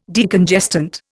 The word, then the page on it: Decongestant: Indian